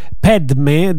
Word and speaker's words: Padme: Ed è bellissimo perché scivola via un po' tutto, ascoltando questa musica, ripetendo Om Mani Padme Hum, che poi dice Padme, dice così la pronuncia precisa, o ho capito male? – Padme. Sì.